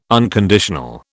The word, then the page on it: unconditional: British English